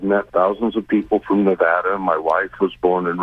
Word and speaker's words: Nevada: And if you go to the Nevada state website, they even have the punctuation over one of the A's to indicate it's pronounced Nevada. – All right. Thank you. – They're very Nevada.